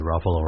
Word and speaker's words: Ruffalo: I always said Ruffalo rhymes with Buffalo.